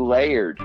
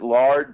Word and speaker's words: Laird: There's a kid named Patrick Laird. I want to pronounce it Lard, but I think it's Laird, L-A-I-R-D.